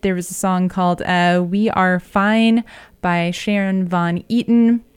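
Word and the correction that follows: Van Etten: or Etten. I'm not pronouncing that correctly, Sharon Von Etten.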